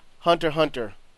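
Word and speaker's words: Hunter × Hunter: Ichigo Gami says it's Hunter Hunter. – Oh. Well, okay then. – The X isn't pronounced.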